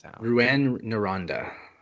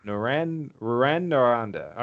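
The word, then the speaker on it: Rouyn-Noranda: How do I pronounce that? – Rouyn Noranda. – Rouyn Noranda.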